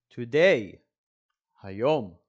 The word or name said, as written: today